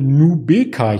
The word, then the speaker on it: Nubeqa: Die sind nämlich sehr optimistisch für das Krebsmittel Nubeqa. Ich hoffe, ich habe es richtig ausgesprochen. – Ich hätte es genauso ausgesprochen, sehr gut.